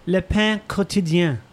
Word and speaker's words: Le Pain Quotidien: Le Pain Quotidien, something like around there. That's kind of what it is. – I guess you could say it, Le Pain Quotidien.